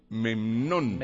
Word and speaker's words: Memnón: Memnón. Oye, este sí no lo había. – Memnón. – ¿Y usted cómo se escribe? No, M-E-M-N-O-N. Memnón.